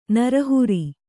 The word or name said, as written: ನಿರಾಹಾರ